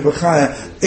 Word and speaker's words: Bachya: If you look at Rabbeinu Bachya, mispronounced usually as Rabbeinu Bachya, in Parshas Terumah, at the beginning of Parshas Terumah, he tells us there are 15 ingredients in the Mishkan.